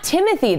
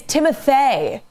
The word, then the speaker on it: Timothée: She was like, it's Timothée. It's not Timothy. – The accent is because, it's definitely spelled like Timothée.